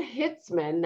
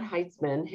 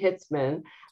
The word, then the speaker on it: Hitzmann: And we also have Sue Hitzmann, not Heitzman, Hitzmann.